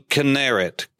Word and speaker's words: Kinneret: And number four, the primary name, Kinneret, K-I-N-N-E-R-E-T. Some would spell it K-I-N-E-R-E-T, but it's Kinneret.